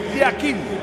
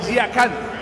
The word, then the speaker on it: Yakin: En el suizo se pronuncia así, Murat Yakin. En turco, Yakan. Cambia la última parte de su apellido.